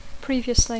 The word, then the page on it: previously: English